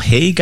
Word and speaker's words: Hauge: Michael Haig, I think is how you pronounce his name, H-A-U-G-E.